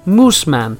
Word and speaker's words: Moosmann: That's how it's spelled M-O-O-S-M-A-N-N. So it's Musmann.